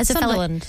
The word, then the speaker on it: Sunderland: This year it's going to be held in Sunderland. Am I saying that right? – Yeah, Sunderland.